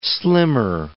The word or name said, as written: slimmer